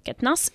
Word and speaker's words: Quatennens: Quatennens, si je prononce bien. – Quatennens.